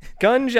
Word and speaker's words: gunge: Let me make sure I'm pronouncing it right. – Oh, what is it? Junge? – Junge? – Or... – Gunge, as it's known in the UK, uh-huh.